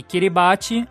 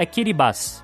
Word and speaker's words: Kiribati: E apesar de a gente ter falado aqui Kiribati, a pronúncia certa do nome do país é Kiribati.